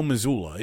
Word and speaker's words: Mazzulla: No, it's Joe Mazzulla.